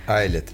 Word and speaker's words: Ailet: И сегодня в нашей студии группа под названием, я, кстати, не спросила, как вы правильно произноситесь, I Айлет или Айлет? – Айлет.